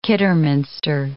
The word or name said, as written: Kidderminster